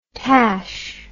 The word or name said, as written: tash